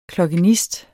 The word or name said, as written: klokkenist